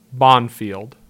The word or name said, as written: Bonfield